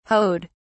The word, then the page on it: hoed: British English